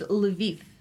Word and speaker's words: Lviv: I'm here with Diego from Mexico City, and I can't pronounce it, L-V-I-V. – It's Lviv.